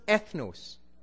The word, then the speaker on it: ethnos: That word there, nations, in the Greek is pronounced ethnos, where we get ethnic from.